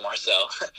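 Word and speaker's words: Marczell: And anyone who's interested in finding me, you can follow me on Instagram at Marcel, but it's spelled Marcel, so it's M-A-R-C-Z-E-L-L. My name's still Marcel.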